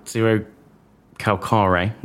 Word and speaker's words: Zerocalcare: So Zero Carcal, Zero Calcare, I'll say his name right, um, writes, has written a series of novels, and both of these are based on one of his.